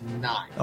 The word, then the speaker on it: Nighy: Is it Nighy or Nighy? – Bill Nighy. – I believe it's Nighy.